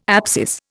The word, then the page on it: abscess: Indian